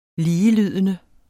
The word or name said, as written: ligelydende